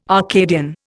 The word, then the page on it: Arcadian: Indian